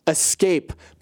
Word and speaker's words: Aescape: And today's trend comes from a company called Escape, but it's spelled A-E-S-C-A-P-E, so you know it's very trendy.